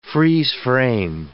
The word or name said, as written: freeze-frame